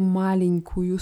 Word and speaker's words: маленькую: Ti stajosh в bagash etu маленькую sumku. Bei malinku ist die Betonung auf der ersten Silbe.